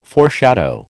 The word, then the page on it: foreshadow: American English